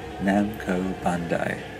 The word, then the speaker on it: Namco Bandai: Um, na- here you go, not Nando's, Namkai Bando, ba- Namco Bandai. I believe that they, uh, with their official outlets, I made it. I actually managed to pronounce it.